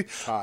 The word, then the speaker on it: Tay: Tay is it Ty Conti? – Ty. – I'll never fucking know. – Spelled Tay, pronounced Ty.